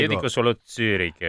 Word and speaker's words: Zürich: ci spiega che Zurbriggen e Zurbriggen, Zürich e Zürich, quindi si pronuncia diversamente il primo con la U italiana, perché il secondo c'ha la dieresi, okay.